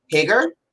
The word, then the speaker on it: Hager: How does Jake pronounce his last name? It's Hager, right? – Hager, yeah. – Hager, cool. I was making sure I wasn't saying it wrong.